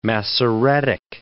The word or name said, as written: Masoretic